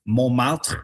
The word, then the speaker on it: Montmartre: And with, you know, same type of process that happened, we have Montmartre, which was the original French pronunciation. And because the sound system of French is so different from the sound system of English, you can see the changes that happen. So we have that nasal on in mon, and we don't have that T. So the T was lost just because it was never pronounced to begin with, but that on became nasal, so we ended up with mo, right?